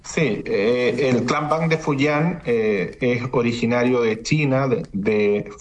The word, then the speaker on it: Fujian: en específico del clan Bang Fujian. ¿No es así? ¿Se pronuncia? ¿Es correctamente? ¿Cómo se dice? – Sí. El clan Bang de Fujian.